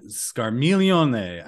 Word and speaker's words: Scarmiglione: He knows that you're trying to become a paladin, and he sends the earth demon, I don't know how to pronounce this, Scarmaglione? – Scarmiglione. I think they all have Italian. I think they all have Italian names.